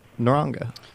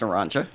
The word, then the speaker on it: Naranga: Would you mind telling us a little bit about Naranga? – Sure. First of all, it's pronounced Naranja. All right. but we hear both.